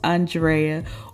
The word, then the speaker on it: Andrea: Eh, I'm your I'm your mid host Andrea or Drea if you continue to butcher the A N of my name.